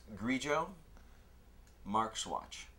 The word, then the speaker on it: Grillo-Marxuach: My last name is pronounced Grillo Marxuach. Marks like Carl or Groucho. Watch like the thing. Well, the thing that people used to wear before cell phones.